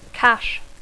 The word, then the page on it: cache: English